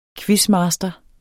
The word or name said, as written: quizmaster